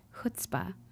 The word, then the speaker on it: chutzpah: Growing up in a Jewish home, I've always loved the Yiddish word chutzpah, rhymes with footspah, and admired those who exhibited this quality.